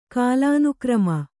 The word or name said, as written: ಕಾಲಾನುಕ್ರಮ